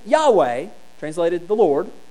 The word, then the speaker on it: Yahweh: We often pronounce this as Yahweh.